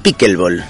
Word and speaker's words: pickleball: ¿Lo digo bien, pickleball? – Pickleball. – Pickleball. – Un poco más españolizado. En Estados Unidos será menos entendible, pero sí, pickleball.